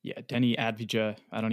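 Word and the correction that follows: Avdija: even know how to, I might have totally butchered his last name there, but Avdia. He went 10, or Avdia, there you go. – Avdia, yes, sir.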